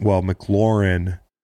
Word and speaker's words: McLaurin: And I wonder if poor Dwayne Haskins McLaurin as well.